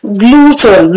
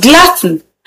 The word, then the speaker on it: glutton: People tend to mispronounce this word because of the letter U in the spelling. But please take note of this. The word is pronounced glutton. Glutton and not gluten. So don't let the letter U in the spelling confuse you to pronouncing the wrong word.